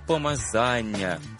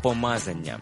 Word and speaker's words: помазання: А як же правильно казати «помазання» чи «помазання»? Правильно казати «помазання». За словником,